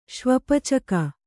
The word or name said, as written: ಶ್ವಪಚಕ